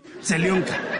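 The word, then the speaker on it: Celionca (зелёнка): Selionka. Selionka, que es como una composición química, como un polvo. – ¿Selionka me lo está diciendo en ruso? – Sí, celionca. – No pronunciación correcta. Celionca. – Celionca.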